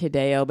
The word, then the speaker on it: Hideo: Oh, yeah, that's right, because he kept saying, like, when he's introducing himself, what is it, it's Hideo, but it's spelled like hero.